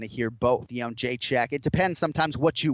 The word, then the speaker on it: Jędrzejczyk: It seems like the adopted pronunciation is Jędrzejczyk, but in actuality, it's Jędrzejczyk. – Yeah, I think you're right. And I mean, I guess we can give it to TJ. If we're being generous, we can give him that pronunciation. – Generous. – But I speak English. That's how you say it in English. All right. – Yeah, but it's how the fighter says it, right? That's what we're going with. That's what John just explained with the whole pronunciation.